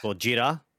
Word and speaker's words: Gojira: Being that I haven't seen the trailer, and being that I'm of Japanese ancestry, I'm going to say Gojira, which is the proper pronunciation for Godzilla. That's G-O-G-I-R-A for anybody that wants to phonetic it out.